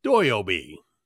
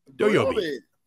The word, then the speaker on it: Doyobi: It would have been Doyobi. Doyobi. Which is how you say Saturday in Japanese.